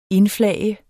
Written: indflage

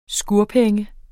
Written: skurpenge

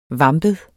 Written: vampet

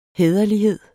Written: hæderlighed

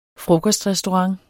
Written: frokostrestaurant

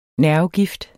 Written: nervegift

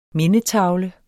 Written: mindetavle

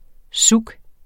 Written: souk

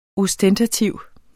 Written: ostentativ

